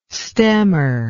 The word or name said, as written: stemmer